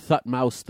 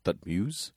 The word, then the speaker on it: Thutmose: Some experts believe that the pharaoh of Exodus was Thutmose III. – Spell it for me. – Thutmose. – Yeah, all right. Thutmose? – Also spelled Thothmes. – T-H-O... T-H-O what? – T-H-O-T. – O-T. – M-O-S-E.